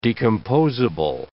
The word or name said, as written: decomposable